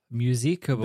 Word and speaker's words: Muziekgebouw: Muziekgebouw.